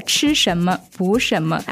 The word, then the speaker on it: 吃什么补什么: They were discussing with the Chinese doctor about a Chinese thought on medicine, which was 吃什么补什么. Is that the right way to say it? – Yeah, 吃什么补什么.